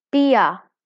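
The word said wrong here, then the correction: Bea: or B, right? I'm saying it wrong. Sorry. B.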